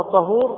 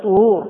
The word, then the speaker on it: طهور: إذا نطقها طهور خطأ، لأن المعنى يكون إذا نطقنا بالفتح لا يقبل الله صلاة بغير ماء أو بغير تراب، لا ليس هذا المعنى، المعنى بغير طهور أي بغير تطهر، المراد بفعل المتطهر، بغير طهور أي بغير وضوء، بفعل كذلك وضوء ووضوء بالفتح والضم.